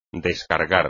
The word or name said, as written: descargar